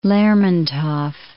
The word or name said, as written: Lermontov